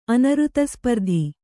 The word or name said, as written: ಅನೃತಸ್ಪರ್ಧಿ